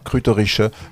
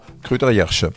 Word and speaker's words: Krautergersheim: Mais ce n'est pas mieux en alsacien, Kruterische, on entend également Kruterjersche.